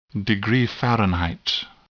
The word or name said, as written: degree Fahrenheit